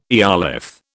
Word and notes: eolith: British English

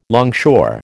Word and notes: longshore: American English

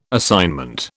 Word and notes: assignment: British English

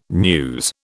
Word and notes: news: British English

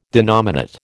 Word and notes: denominate: American English

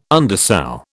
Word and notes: undersow: British English

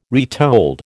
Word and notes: retold: American English

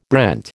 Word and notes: brent: American English

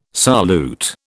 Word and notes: solute: British English

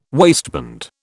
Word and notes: waistband: British English

